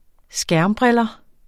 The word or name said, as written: skærmbriller